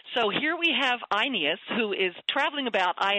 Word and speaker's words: Aeneas: And I don't remember how you say his name, Aeneas? – Aeneas. Right? I like the Scots word for yes, Aeneas.